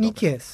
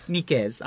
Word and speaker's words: Nicaise: La editura Univers Enciclopedic Gold, opere Leibniz, corespondență cu Fouché, Nicazé, Bossuet, cum se pronunță corect? – Nicaise. – Nicaise, așa, Nicaise, Fouché, Bossuet, Nicaise, Bossuet, Sophie și Sophie Charlotte, da? – Da.